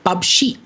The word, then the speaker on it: PUBG: Tenemos el agrado de tener aquí con nosotros a los señores Silsen y Ragnar, los jugadores profesionales de PUBG, ¿se dice así? – Sí, está bien.